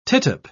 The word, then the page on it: tittup: British English